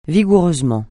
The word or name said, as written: vigoureusement